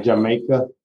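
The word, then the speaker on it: D'yer Mak'er: entonces, se pronuncia Jamaica.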